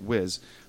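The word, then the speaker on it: Wyss: Wiz. It looks like Weiss, but she pronounced it Wiz.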